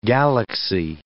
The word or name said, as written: galaxy